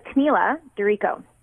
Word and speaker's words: Camilla DiRico: Well, it's a little, no, it's a Camilla DiRico. So my family's Italian. So we've got, I'm not even pronouncing it. I should be rolling my R's, you know? – No, I can't.